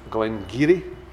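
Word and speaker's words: Glen Garioch: This one here, which, believe it or not, is pronounced Glengarry, is the pronunciation.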